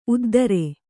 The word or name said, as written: ಉದ್ದರಿ